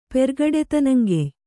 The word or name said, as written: ಪೆರ್ಗಡೆತನಂಗೆಯ್